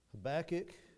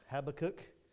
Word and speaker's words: Habakkuk: Habakkuk, or if you prefer to pronounce it, Habakkuk, either one, I think you will... You'll be right.